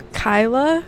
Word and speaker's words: Kyla: I'm Kyla, spelled K-Y-L-A.